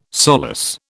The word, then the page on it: solace: British English